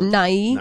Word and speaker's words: Nye: Bill Nye. – Nye. – Nye. Yeah. Like, bye.